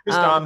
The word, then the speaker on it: Tristan: Tristan, that's right.